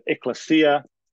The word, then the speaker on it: ecclesia: So ecclesia, which we get the word ecclesiology. Some people pronounce it ekklesia.